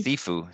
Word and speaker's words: Sifu: Is it Sifu? Sifu? – Sifu.